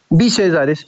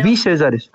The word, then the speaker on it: Bee Sellares: B. Sellares, sí, pero algunos me dicen B, no te preocupes, bueno, es B. Sellares.